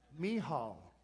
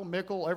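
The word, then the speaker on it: Michal: Mihal. It's simple once you know. Mihal, because I said Michael, Mickle, everything else last time. As the ark of the Lord was entering the city of David, Mihal,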